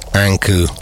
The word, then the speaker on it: Anku: Time for everyone's favorite artist that rhymes with thank you. It's Anku.